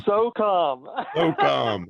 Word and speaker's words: USSOCOM: How do you say that? USSOCOM. – So calm.